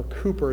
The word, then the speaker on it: Cowper: I'll read a poem at the end of this by William Cowper, or Cooper as it's pronounced in Britain, who died with mental infirmities.